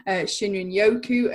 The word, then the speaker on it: Shinrin-yoku: and Shinrin-yoku, as you pronounced so beautifully at the beginning there.